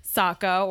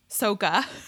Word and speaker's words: Sokka: But it is a fact that Jackson Rathbone did play Sokka, or as the movie mispronounced his name, Sokka.